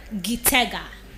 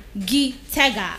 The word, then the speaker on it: Gitega: Now, the capital city of Burundi is Gitega. Gitega. I'll spell that for you. G-I-T-E-G-A. G-I-T-E-G-A is the capital city of Burundi, and that is Gitega.